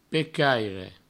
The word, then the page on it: pecaire: provencal